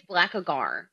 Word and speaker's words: Blackagar: Excuse me, it's Blackagar. – It's Blackagar. – What I say his name was? – I don't know. Something Blackagon sounds real Bolt silly. Again? – You said Blackagon.